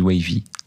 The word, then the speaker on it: Waiv: ouais, voilà, du Wavy. Ça s'écrit W-A-I-V.